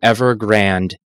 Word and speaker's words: Evergrande: On top of that, China Evergrande, and I'm not mispronouncing that, it is actually Evergrande A with an E at the end,